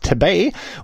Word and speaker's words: Tebey: Ich hoffe, der wird auch richtig ausgesprochen. Tabay, also T-E-B-E-Y.